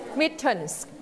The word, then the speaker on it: mittens: mittens ออกเสียง s เพราะเราใส่กี่มือเราใส่ 2มือนะคะ mittens